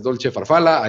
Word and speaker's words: Dolce Farfalla: Dolce Farfalla, así se escribe, pero es Dolce Farfala,